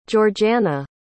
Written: georganna

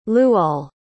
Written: luol

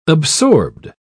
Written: absorbed

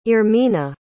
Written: irmina

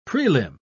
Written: prelim

